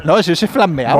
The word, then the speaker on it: flamea: Se dice así, ¿no? – Se farmea. – Claro. – Se flamea.